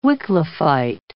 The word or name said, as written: Wycliffite